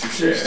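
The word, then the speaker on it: touché: And it's pronounced just touche. There's no extra ER at the end. – Okay. – It's touche.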